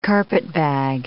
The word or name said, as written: carpetbag